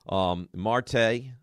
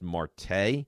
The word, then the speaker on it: Marte: Marte. Others I've heard call him Mart, but I believe the name is pronounced Marte.